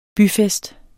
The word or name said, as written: byfest